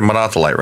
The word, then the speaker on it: Monothelite: Monothelite, right? – Yes. – Okay. And this is just an example of what you say, that the emperor of Constantinople took the side of the Monothelites.